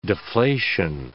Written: deflation

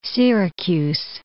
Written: Syracuse

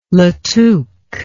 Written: latuque

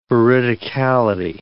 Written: veridicality

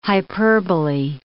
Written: hyperbolae

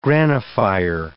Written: granophyre

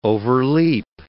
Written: overleap

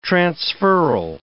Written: transferal

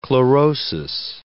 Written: chlorosis

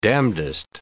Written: damndest

